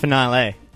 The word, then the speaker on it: finale: Star Wars fans are pissed Jason Segers punched Baby Yoda in the Mandalorian final. So he's pissed off. – It's pronounced finale. – Yeah, thanks. Finale.